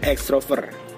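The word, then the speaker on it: ekstrover: Kalau berdasarkan KBBI itu dibacanya extrovert. E X E K S T R O V E R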